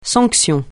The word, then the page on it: sanction: francais